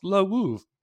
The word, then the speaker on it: Lwów: It's pronounced Lwów.